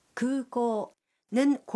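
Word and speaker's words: 空港: 쿠코는 공항이라는 뜻인데 쿠코의 우와 오는 길게 발음합니다.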